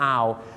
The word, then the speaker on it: hour: hours ถ้าไปออกเสียงว่า 25 hours เนี่ยนะครับผมฝรั่งจะฟังเป็นนกฮูก 25 ตัวนะครับเพราะ owl เนี่ยออกเสียงว่า owl ไม่ใช่ owl นะครับ owl นั่นเองครับดังนั้นครับชั่วโมงต้องออกเสียงว่า hour นะครับ hour